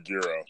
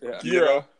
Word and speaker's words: gyro: About that, the way it's pronounced in Greek is gyro, which is the way you said it, which is correct. The way people in America pronounce it is gyro.